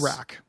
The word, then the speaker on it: Urak: It says Urak. Urak. Okay. Yes. – Urak. Long U.